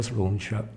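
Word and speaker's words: sláinte: L'unica cosa curiosa delle due cose è che uno si pronuncia slainte, si pronuncia sloncia, uno si pronuncia failte, si pronuncia felcia.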